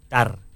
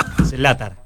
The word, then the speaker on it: Zlatar: Zlatar. Como es Zlatan, con la R al final.